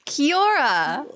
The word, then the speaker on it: Kia ora: Kia ora.